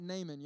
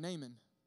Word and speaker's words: Naaman: So tonight we're going to learn about Naaman. Y'all say his name, Naaman. Naaman, N-A-A-M-A-N.